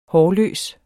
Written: hårløs

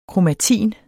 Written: kromatin

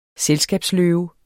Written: selskabsløve

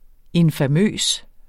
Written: infamøs

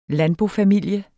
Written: landbofamilie

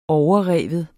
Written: overrive

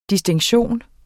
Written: DISTINKTIONEN

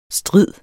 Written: strid

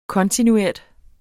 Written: kontinuert

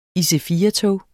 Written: IC4-tog

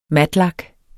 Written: matlak

